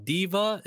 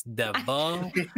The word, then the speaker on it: Devuh: Well, you guys can primarily find me on YouTube on Hey Deva, H-E-Y space D-E-V-U-H. It's not Diva, it's Deva.